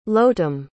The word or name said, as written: Lotem